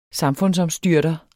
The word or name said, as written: samfundsomstyrter